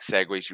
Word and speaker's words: segue: So for years, I've been thinking that it was spelt like wrong, whereas it's spelt S-E-G-U-E, like segue, like a French like spelling. – I see people call it segue, like S-E-G-W-A-Y. – Yeah, yeah, that's how I thought it was pronounced.